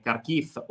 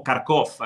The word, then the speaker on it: Kharkiv: E volevo anche dirvi che Kharkiv, o Kharkov, è la pronuncia in russo, è una città importante, la seconda città più grande dell'Ucraina dopo Kiev, 1,5 milioni di abitanti.